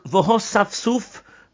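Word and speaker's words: v'hosaf suf (וְהָאסַפְסֻף): The first word of the pasuk, v'hosaf suf, notice that the aleph is silent. You don't pronounce it at all.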